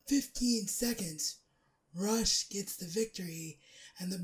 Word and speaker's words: Rush: Next up, it was a grudge match as Rush, or Roosh, as I believe it's pronounced, took on the former Ring of Honor World Champion, Dalton Castle.